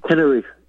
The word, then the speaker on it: Tenerife: Got the pretty correct pronunciation. What's that? – It's Tenerife.